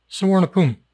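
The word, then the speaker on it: Suvarnabhumi: Sawarnapum.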